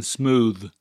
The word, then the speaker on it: smooth: In English, the two-letter combination TH can be pronounced in two different ways. Once is in the word booth, and the other is in smooth.